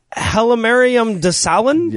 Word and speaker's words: Hailemariam Desalegn: Hailemariam Desalegn? – Yeah, that's what I would have said.